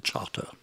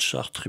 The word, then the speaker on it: Chartres: C'est marrant là-bas parce qu'il y a Chartres euh, Street, qu'il faut prononcer Charter. Si vous dites Chartres, il vous amène à 10 km de là, le chauffeur taxi.